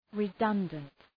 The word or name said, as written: redundant